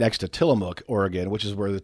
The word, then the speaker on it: Tillamook: The locals say it like Tillamook. – Tillamook.